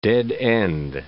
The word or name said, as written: dead end